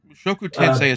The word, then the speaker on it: Mushoku Tensei: Went through Mushoku Tensei is how I've heard it pronounced.